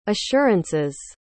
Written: assurance's

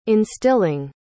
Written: instilling

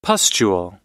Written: pustule

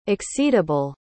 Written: exceedable

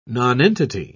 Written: nonentity

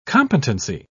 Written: competency

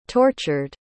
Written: tortured

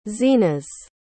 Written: zenas

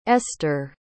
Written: eszter

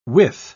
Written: withe

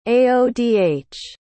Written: aodh

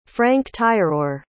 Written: franc-tireur

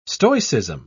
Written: stoicism

